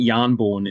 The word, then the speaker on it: Mjolnir: And so, you know, and it's the same with, you know, as a kid, Thor was always carrying around Mjolnir. So yeah, I think Yarnborn is the way I pronounce it.